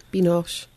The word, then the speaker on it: Binoche: Binoche.